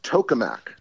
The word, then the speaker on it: tokamak: Is that what they call a tokamak? Is that Yes, it's pronounced tokamak. – Tokamak.